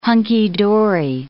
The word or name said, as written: hunky-dory